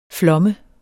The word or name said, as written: flomme